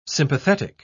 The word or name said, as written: sympathetic